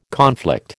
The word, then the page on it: conflict: American English